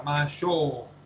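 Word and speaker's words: Mashou (ましょう): Como no tengo cómo escribir eso, lo que hice simplemente fue escribir o-U, pero no es que se pronuncie Mashou, no se pronuncie show. Cuando yo escribo o es porque estoy refiriendo a una O larga, Mashou.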